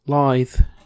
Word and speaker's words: lithe: Lithe. – I think it's lithe. – Lithe. Like live, but with T-H.